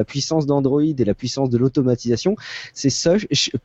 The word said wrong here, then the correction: Shush: pardon, je le prononce mal encore. Shush alors ça s'écrit S-H-U-S-H. Point d'exclamation.